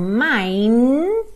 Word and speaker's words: mine: Quando a gente diz in this heart of mine, M-I-N-E, só que o final não é pronunciado, mine, esse é um pronome possessivo, tá bem?